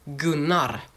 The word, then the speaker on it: Gunnar: It's a very weird name, and it's actually Swedish, and because I live in Sweden, I know how it's pronounced, and it's pronounced Gunnar.